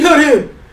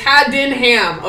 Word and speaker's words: Haddenham: I'm like, first of all, that's not how you say my name, but okay. He's like, Trainee Haddenham. I'm like, I didn't have a ham. It's Haddenham, okay? It's fucking, it's English.